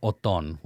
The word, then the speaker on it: Othon: So my name is Othon, which is spelled O-T-H-O-N.